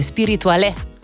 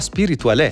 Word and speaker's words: Spirituale: L'ho anche messa all'accento, è Spirituale. – Ah, Spirituale.